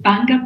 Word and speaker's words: Bbanga: So the one in Uganda is called Banga Project. It's spelled B-B-A-N-G-A. And most times people don't know how to pronounce two B's following each other, so you just call it B. So it's just Banga Project.